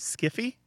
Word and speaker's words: sci-fi: Some people jokingly pronounce it as skiffy, especially if they're talking about bad science fiction.